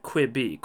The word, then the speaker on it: Quibi: We've always pronounced it Quibi, Quick Bites, Quibi, etc., etc., etc.